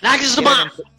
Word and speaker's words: Nagelsmann: Nagelsmann.